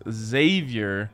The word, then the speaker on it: Xavier: All right, Villanova to win by 7+ versus Xavier. You don't pronounce the X.